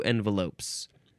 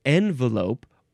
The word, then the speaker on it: envelope: Because we have a couple of pronunciations. We have envelope or envelope. So the N and on pronunciations are used with about equal frequency, and both are fully acceptable.